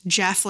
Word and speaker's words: Gef: It's spelled G-E-F, but it's pronounced Jeff, like with a J.